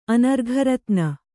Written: ಅನರ್ಘರತ್ನ

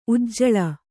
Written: ಉಜ್ಜಳ

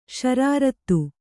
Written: ಷರಾರತಿ

